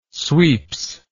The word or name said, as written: sweeps